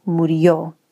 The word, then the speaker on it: murió: And it also seemed appropriate because the accent the emphasis on the word is on the final syllable, murió.